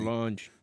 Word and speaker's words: Solange: Solange.